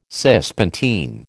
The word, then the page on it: Cispontine: American English